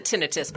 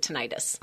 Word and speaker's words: tinnitus: We pronounce it tinnitus, but most patients pronounce it tinnitus. – Okay. – Either one.